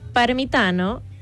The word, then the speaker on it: Parmitano: Y bueno, te cuento que estos cuatro astronautas son Randy Bresnik, que es comandante de la misión, Luca Parmitano, piloto, Frank Rubio, que es especialista de misión, y André Douglas, que es especialista de misión. – Esta Parmitano, y que lo pronunciaste muy italiano.